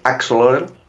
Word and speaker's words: Axolotl: Pues para terminar les vamos a recordar a los oyentes AK Solotel, que es como lo llamo yo, no sé si es la buena pronunciación del juego, la pronunciación correcta. – En teoría es Axolotl.